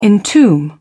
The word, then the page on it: entomb: British English